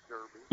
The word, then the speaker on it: Derby: Does anybody in Kentucky pronounce it Derby? – Have an announcer at the Kentucky Derby who, I believe he's English, and he does sometimes slip into that mode. Most people, pronounce it the American way, Derby.